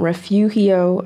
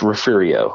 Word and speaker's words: Refugio: So this week they'll have um, Refugio. I think I'm saying that right. – Uh, I'll, I'll, correct you so you don't get you don't get butchered by the. It's Refugio. – Refugio. Ref, okay. – Refurio, Yeah. They'll, it's weird pronunciation. It's one of it's one of our weird Texas pronunciations.